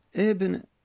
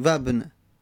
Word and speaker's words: ابن: همزه وصل که گفتیم در هنگام تلفظ زدن، در هنگام تلفظ زدن اگر اول کلام اومد همزه باقی میمونه، میگیم ابن. اول کلام میگیم ابن، همزه رو تلفظ میزنیم. در وسط کلام همزه وصل میفته، مثلا و بیاریم قبل از اون میگیم وابن، وابن.